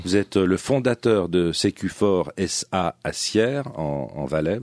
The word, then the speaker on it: Sécufort: Ça s'écrit S-U-C-U. 4, hein, puis ça se prononce Sécufort.